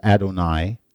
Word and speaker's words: Adonai: And I told you before that name, that's also not referred to as the Tetragrammaton, this name, which we pronounce Yahweh, which we really don't know how to pronounce it, so the Jews, we always pronounce it Adonai.